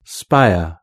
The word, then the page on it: spire: British English